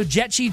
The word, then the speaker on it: Jechi: It's like jet without a T, they say, and then chi, like, ooh, I found my inner chi. – Yeah. – Find your chi. So Jetchi juice, and it's spelled J-E-C-H-I juice if you're looking for it.